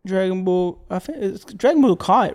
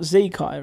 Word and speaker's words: Dragon Ball Kai: Dragon Ball Kai, right? It's not Z Kai, right? – We watched more episodes this week.